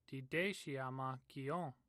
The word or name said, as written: dideshi